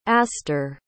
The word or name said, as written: astor